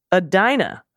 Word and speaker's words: Edina: Edina is Adina.